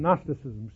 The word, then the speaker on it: Gnosticism: Now, you'll run into this term Gnosticism, spelled with a G, G-N-O-S, so forth.